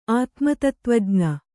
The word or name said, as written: ಆತ್ಮತತ್ತ್ವಜ್ಞ